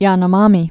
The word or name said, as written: Yanomami